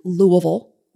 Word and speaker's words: Louisville: I worked in both Lexington and Louisville, which is the correct pronunciation.